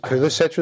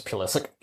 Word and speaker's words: Pulisic: Oh goodness, because Pulisic was playing. – Well, you've pronounced his name wrong. It's Pulisic.